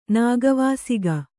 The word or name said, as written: ನಾಗವಾಸಿಗ